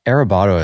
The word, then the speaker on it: Arrebato: I think that Arrebato especially is, how'd I do on that pronunciation, by the way? Is that all right? – It was pretty good. Yeah, it was a good Americanization.